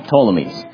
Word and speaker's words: Ptolemies: It starts with a P, but you don't pronounce the P. Ptolemies.